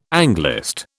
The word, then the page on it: Anglist: British English